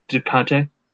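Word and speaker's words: DeCoteau: You had director David Dakota. Is that how you pronounce his last name? – Ducante. Yep.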